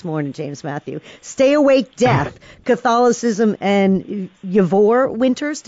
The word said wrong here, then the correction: Yvor: Ivor Winters.